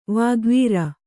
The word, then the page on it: ವಾಗ್ವೀರ: vāgvīra